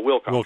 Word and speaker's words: Wilcoxon: Emphasis on the first syllable, Wilcoxon.